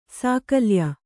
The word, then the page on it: ಸಾಕಲ್ಯ: sākalya